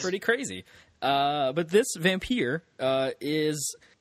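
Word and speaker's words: Vampyr: I believe that's Vampire.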